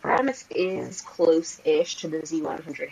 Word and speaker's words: Paramus: So, again, you're not native Jersey, but why are we playing Paramus Park Mall?